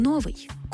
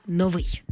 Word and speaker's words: новий: В усному мовленні раз по раз чуємо «новий». Колись це слово справді мало два наголоси, але вже давно наголос на першому складі вважається ненормативним, тому слід казати «новий».